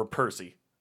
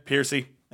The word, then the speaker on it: Piercy: Piercy or Percy? It's spelled like Percy, but with two E's. – Piercy? – Yeah, Piercy.